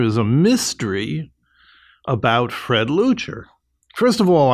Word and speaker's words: Leuchter: i am going to mispronounce his name. Fred Luchter? – Fred Leuchter.